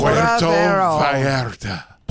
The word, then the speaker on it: Puerto Vallarta: Puerto Vallarta. Puerto Vallarta. – Nice. – Were you saying that on the air or in my ear? – It was on the air. – Puerto Vallarta.